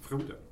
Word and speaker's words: Frodo: Frodo.